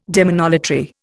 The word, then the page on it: demonolatry: Indian